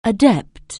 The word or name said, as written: adept